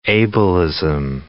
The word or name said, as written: ableism